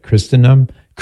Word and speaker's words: Christendom: That's their website, Christendom, the T is silent, .edu.